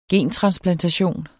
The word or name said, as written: gentransplantation